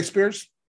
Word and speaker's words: Spears: Yeah, and the number three guy, TJ Spears, that is how you say his name.